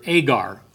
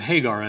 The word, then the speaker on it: Hagar: And you pronounced it like it started with an H. So very often the translation, I was just remarking that in the translation here, it always calls her Agar instead of Hagar as in the Old Testament.